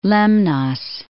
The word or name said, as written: Lemnos